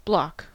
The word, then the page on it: bloc: American English